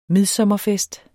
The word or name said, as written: midsommerfest